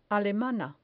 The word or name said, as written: alemana